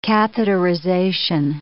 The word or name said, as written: catheterization